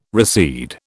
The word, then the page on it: recede: British English